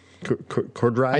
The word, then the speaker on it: Corddry: And then, and I'm not sure how to pronounce his name, but Rob Corddry? Corddry? – I think that's correct. Yeah, he's been in a bunch of stuff, The Office, Children's Hospital, Hot Tub Time Machine.